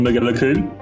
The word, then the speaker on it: Mac Giolla Chomhgaill: Hello, my name is Niall McGillicoon.